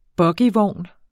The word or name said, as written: bogievogn